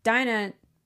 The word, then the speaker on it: Dinah: But, you know, for Dinah, did I say it right? – Yes.